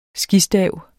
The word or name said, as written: skistav